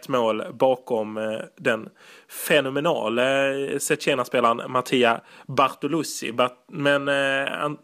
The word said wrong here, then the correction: Bartolucci: inte ba, ursäkta avslutet här på hans. Bartolucci. Bartolucci, absolut.